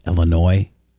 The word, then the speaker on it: Illinois: Does she not know that it's a French word and the S is silent? It's pronounced Illinois? – Take it from Bubba.